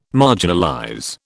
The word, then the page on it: marginalize: British English